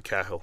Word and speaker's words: Cahill: Dennis Cahill or Cahill, but the Irish pronounce it Cahill.